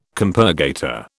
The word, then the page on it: compurgator: British English